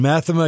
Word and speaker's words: Math3ma: And Mathema, again, is spelled M-A-T-H, the number 3, M-A.